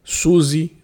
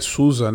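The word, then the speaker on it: SUSE: Por exemplo, Red Hat, CentOS, Suzy. Né? Ah, uma curiosidade aí, a pronúncia nativa é Suza, né? porque é alemã essa distribuição.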